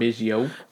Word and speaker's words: Biggio: We got Craig Biggio. – Biggio.